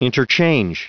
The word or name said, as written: interchange